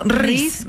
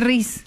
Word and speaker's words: rizz: O sea, ris. – ¿Cómo se escribe ris? – R-I-Z-Z. – R-I-Z-Z.